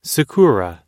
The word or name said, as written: sakura